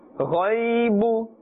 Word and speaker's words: غيب: Do not say غي غي will be incorrect and gai. Gai will also be incorrect. Rather, with a full mouth you must pronounce this letter. Ghaibu.